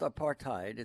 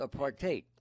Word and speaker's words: apartheid: And the one of the things that she learned there was it's not pronounced apartheid. It's pronounced apartheid. – Indeed. – And one of the things about that is it's easy to remember because there's a hate in apartheid.